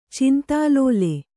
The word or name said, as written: ಚಿಂತಾಲೋಲ